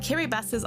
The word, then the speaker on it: Kiribati: And yes, it is pronounced Kiribati. That's how it's pronounced in the local language Gilbertese.